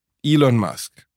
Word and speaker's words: Elon Musk: Sagt man Elon, wie sagt man? – Elon Musk. – Okay, du hast das jetzt festgelegt.